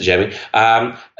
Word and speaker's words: Xiaomi: Yep, that's it, Xiaomi.